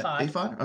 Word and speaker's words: ephod: And that reads, Samuel was ministering before the Lord, a boy wearing a linen ephod. – Ephod.